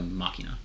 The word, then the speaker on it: Machina: Like, I think the emphasis is on the the Machina. Yeah. – Okay, but you do pronounce the I like an E. – Yeah. – Yeah. – Not like an E letter, like an E sound.